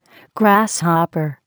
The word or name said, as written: grasshopper